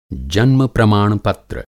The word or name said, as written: जन्म-प्रमाण-पत्र